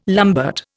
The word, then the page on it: lambert: Indian